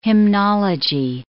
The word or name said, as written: hymnology